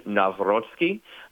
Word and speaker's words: Nawrocki: And by the way, the pronunciation, Nawrocki.